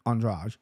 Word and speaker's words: Andrade: Then this fight is about to happen, and he looks over at his guys, and he goes, hey, bros, take Andreas. Okay, can't even pronounce her name right, Andrade.